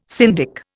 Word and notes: syndic: Indian

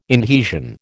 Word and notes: inhesion: American English

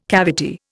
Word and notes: cavity: Indian

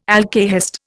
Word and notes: alkahest: Indian

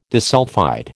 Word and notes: disulphide: American English

